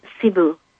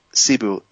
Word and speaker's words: Cebu: Oh, it's Cebu. – Cebu, yeah. C-E-B-U, for those listening. – Correct.